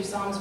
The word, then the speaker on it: Psalm: So I'm going to read you Psalms 150, verse 6 in Afrikaans. And they pronounce the P, so it's not silent.